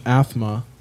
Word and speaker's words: Aathma: Yeah, they're like a progressive death metal band So, during this year, they put out a new album called Athma. Which I think is funny because it sounds like somebody with a lisp saying asthma. All it's spelled A-A-T-H-M-A.